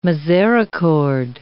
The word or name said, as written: misericorde